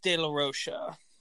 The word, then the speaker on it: De La Rocha: I've heard it pronounced De La Rocha, De La Rocha, De La Rocha. I think it's De La Rocha.